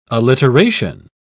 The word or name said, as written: alliteration